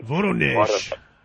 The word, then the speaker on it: Voronezh: I'm also doing a reappraisal of the Voronezh, I forget the Russian pronunciation. – Voronezh.